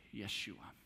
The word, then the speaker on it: Yeshua: You see, Joshua is the way we, in English, pronounce a Hebrew name. This Hebrew name is pronounced Yeshua. Now, this name translates to the Greek in a beautiful way because the Greek way to say this name is Jesus.